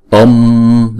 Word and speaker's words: Om: Chúng ta đọc chữ O kéo dài hai nhịp, xong rồi chúng ta mới đóng chữ O đó lại. Chứ chúng ta đừng đóng sớm quá thì chúng ta đọc Om là không đúng ha.